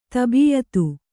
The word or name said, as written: ತಬೀಯತು